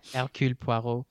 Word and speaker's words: Hercule Poirot: It is about, I don't know how to pronounce his name, Hercule Poirot? – Yeah, that's fine. – Okay. – Hercule Poirot.